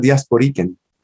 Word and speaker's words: diasporican: Your work focuses on what you refer to as a living in-betweenness, the Puerto Rican diasporica. Did I pronounce that right? – Yeah, diasporica, but it's really diasporican.